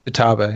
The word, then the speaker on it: Futaba: I loved spending time with, uh, I'm going to say her name wrong, but Futaba? Futa? – Yeah, Futaba, yeah.